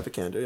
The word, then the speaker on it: Vikander: Alicia Vikander, is that how you say her name? – Yeah, Vikander, yeah.